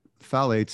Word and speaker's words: phthalates: So I was listening to this podcast about a month or two ago, and they had a doctor on, and they're called phthalates.